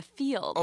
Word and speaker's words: Feeld: It's like you're playing the field. – Oh, playing the field. – But it's spelled F-E-E-L-D. – Oh, God. But so it's not Field. – Real bad name. – It's Field.